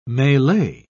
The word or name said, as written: melee